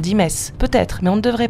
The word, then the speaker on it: Metz: Vous avouerez quand même que si l'on veut être honnête, on doit prononcer le T et dire Metz.